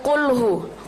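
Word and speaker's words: kulhu (قل هو): Jadi dari kulhu, kulhu itu kaf apa kof, huruf pertamanya? Kof. Jadi kalau huruf kof, kita bukan kulhu. Kalau kita bacanya cuma biasa kulhu, berarti itu kita membacanya dengan huruf kaf. Tapi tidak, kulhu itu pakai kof. Jadi kulhu wallahu ahad. Ko pakai ko, ko kiku, bukan kakiku, tapi ko kiku, kulhu.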